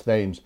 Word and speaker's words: Thames: I should mention it's the Thames River, spelled as the Thames in London, but pronounced Thames.